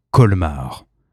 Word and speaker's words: Colmar: C'est le groupe Colmar. Alors, certes, ça se prononce comme la ville. Certes, ça s'écrit quasi pareil que la ville Colmar en Alsace.